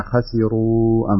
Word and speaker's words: خسروا: لكن في ملحوظة يسيرة في قوله تعالى الذين خسروا أنفسهم، يعني كأنك تنطق السين صاد، خسروا خسروا لا خسي خسروا خسروا الذين خسروا بهذه الطريقة تنطق السين لأنها مرققة،